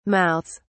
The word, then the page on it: mouths: British English